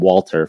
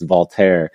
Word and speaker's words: Walter: The only thing that can be interesting about that is that, remember Remy, we were all calling him Walter forever, but then it's Voltaire.